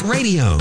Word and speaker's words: Raydio: Ray Parker Jr. was already a go-to session guitarist and band leader behind his band, R-A-Y-D-I-O, pronounced Radio.